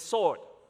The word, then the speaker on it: sword: So the W is silent, so everybody says sword, not sword, right?